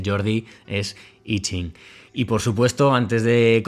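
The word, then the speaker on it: Yijing: Entonces, los caracteres Yi, Qing, para que suene lo más parecido a una a lo que es el carácter chino, se escribe ahora actualmente Yi Jing, pero la pronunciación es la que conocemos de siempre, Yi Jing.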